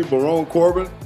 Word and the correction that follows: Baron Corbin: Baron Corbin. – Baron Corbin. – Uh, yeah, yeah. Baron Corbin.